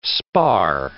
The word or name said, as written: spar